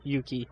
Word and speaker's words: Yuki: Valkyrie Yucky. – I thought it was Yuki. – I'm not sure how to pronounce it. – I'm pretty sure it's Yuki because Yucky sounds bad.